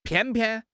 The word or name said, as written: 偏偏